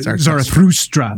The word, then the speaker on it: Zarathustra: Well, and it gets even cooler because this Vimana was supposedly rumored to have belonged to Zarathustra. Super hard name to pronounce. – Zarathustra. – Zarathustra. – Better.